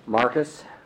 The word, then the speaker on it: Marquez: I'm David Marquez. My last name is spelled M-A-R-Q-U-E-Z.